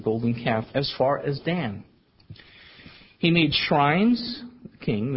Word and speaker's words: Dan: And the other he put in Dan, or actually pronounced Don.